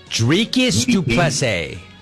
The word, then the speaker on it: Dricus du Plessis: How do you say his name? – Dricus du Plessis.